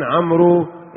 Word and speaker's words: عمرو: هل يقول قائل مدام أن في واو بعد الراء نقول جاء عن عمرو ابن العاص ولا عن عمرو؟ فتضاف واو لإشارة إلى، هل وإن كانت تسكر الياء إشارة لضم الميم أو فتح العين عفوا، وتسكين الميم والراء عمرو، هذه تكتب ولا تنطق.